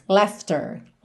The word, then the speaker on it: laughter: Essa palavra laughter também temos de novo o GH com som de F, olha. Laughter, L-A-U-G-H-T-E-R. E a pronúncia é bem simples: laughter, laughter. Uhum.